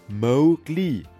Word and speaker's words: Mowgli: Mowgli wird eigentlich Mowgli ausgesprochen.